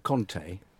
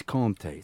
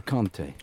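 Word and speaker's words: Kanté: It's not Conte. – Right. – It's Conte. It's a different... It's like Tour and Tour. There's a slight difference in the...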